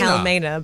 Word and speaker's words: Wilhelmina: Well, see, yeah, technically the pronunciation Wilhelmina. Is Wilhelmina, but I need to put a German accent on when I do that's still safe.